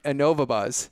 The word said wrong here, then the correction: Innova Biz: right? I get it. – Yeah, Innova Biz is the business. Innova Biz. Okay, Innova Biz at Innova Biz was just like really beautiful.